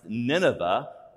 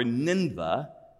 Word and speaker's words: Nineveh: See, Jonah perceives this city that he's being sent to, depending on what scholar you ask, it's pronounced Nineveh or Ninveh, you pick.